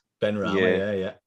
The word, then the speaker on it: Benrahma: Benrahma, yeah.